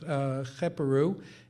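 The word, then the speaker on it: Kheperu: So you have the sun disk, then you have the beetle and three plural strokes, which is pronounced Cheperu, and then underneath it a basket, which is pronounced Neb.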